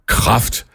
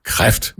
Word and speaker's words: kræft: omtale denne her modbydelige sygdom, kræft, som kraft. Kraft er jo noget helt andet, det betyder styrke, mens denne her modbydelige sygdom udtales med æ, kræft. Men der er altså rigtig, rigtig mange, som mener, at det skal udtales kraft. Jeg holder helt og holdent med dig, Allan. Det lyder så afsindigt dumt, når folk udtaler K-R-E-F-T som kraft.